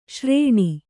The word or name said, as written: ಶ್ರಾಣ